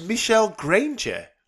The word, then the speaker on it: Michel Granger: Like, it is Michel Granger.